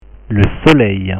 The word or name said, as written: le soleil